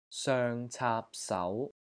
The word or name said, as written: SOENG6 CAAP3 SAU2